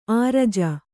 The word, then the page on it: ಅರ್ಜಿ: arji